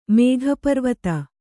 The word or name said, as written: ಮೇಘಪರ್ವತ